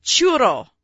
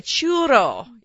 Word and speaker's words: churro: it's pronounced churro. – Right. – Well, right? – That's pretty good. Churro. – It's Spanish. Mexican. – I have my housekeeper. She taught me that I'm saying it wrong. I told her I have an Australian accent. You know, I have an Australian accent, so I'm not going to say it right. In any case, so, but she said churro. Like she got me to roll my R's.